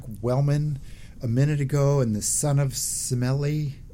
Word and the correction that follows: Semele: Son of Semele.